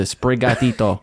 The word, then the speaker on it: Sprigatito: That's how you pronounce it. Sprigatito.